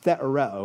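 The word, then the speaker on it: thetareo (θεωρέω): He uses a Greek word that's pronounced thetareo, and we get our word theory or theorize from it.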